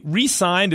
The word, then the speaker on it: re-signed: By the way, re-signed is one of those things. It's spelled exactly like resigned. You ever read that? You hear someone re-sign? You're like, wait, they resigned or re-signed? What's going on?